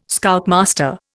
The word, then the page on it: Scoutmaster: Indian